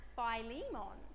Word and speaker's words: Philemon: And then there's this guy, he's got his own book, and it's called and I used to pronounce it wrong, but I found it, it's actually pronounced Philemon.